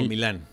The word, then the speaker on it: Milan: O sea, como dicen, ¿Milan o Milán? – El Milan, es que le dicen Milan. El Milan. Chale. Le dicen el Milan, según yo, porque no tiene como el...